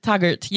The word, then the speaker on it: Taggart: Taggart. Did I pronounce that right? – Taggart, yeah.